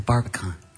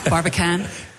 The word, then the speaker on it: Barbican: We had a wonderful time last night at the Barbican. – The Barbican? – Yes. Did I say it wrong? – Yeah. – Barbican? – That'll do.